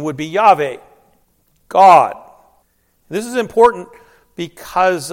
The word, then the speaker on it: Yahweh: And it is best pronounced Yahweh, also known as the Tetragrammaton, a new word we get to learn this year. And it simply means those four letters, YHWH. And the vowels are then supplied to come up with that pronunciation, Yahweh.